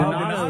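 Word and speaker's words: Donatos: Where are y'all on Donato? Is that how I'm saying that? – Donato. Oh, love Donato.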